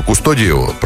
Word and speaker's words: Кустодиев: В 1921 году художнику Борису Кустодиеву, правильно же, по-моему, ударение, Кустодиев, значит, обратились 2 молодых ученых с просьбой написать их портрет.